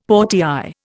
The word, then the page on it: portière: Indian